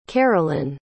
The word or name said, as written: caralyn